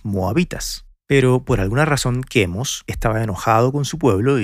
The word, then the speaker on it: Chemosh: Chemosh, que en inglés se escribe Chemosh y en español se dice Chemos, era el dios de los moabitas, un reino antiguo que quedaba junto al Mar Muerto, donde hoy en día queda Jordania.